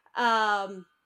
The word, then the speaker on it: Uhura: In the same way, but they did have like kind of an opening number type song, and then I think it's Uhura. – Uhura, is that how you pronounce that character's name? Mm. Uhura.